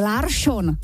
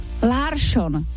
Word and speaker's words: Larsson: Protože celý večer říkali Larsson. Larsson. Chápeš to? Jako Larsson. Larsson. Vždycky to byl jako Švédi vždycky měli S a najednou tam bylo Š.